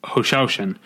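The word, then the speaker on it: Hou Hsiao-hsien: Which is a marvelous film, and it's, Hu Hsiao-hsien is, as we learned to pronounce it at the screening, Hu Hsiao-hsien, is Taiwanese, and this movie felt, the guy who introduced it talked about all the movies.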